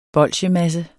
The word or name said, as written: bolsjemasse